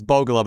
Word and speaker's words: Bogolub: You know, my name is Bogolub, but nobody ever gets that right, so it's all right. I always tell people, they say, how do you spell it? Buy one, get one, L-U-B. That's the only way people can get that part right.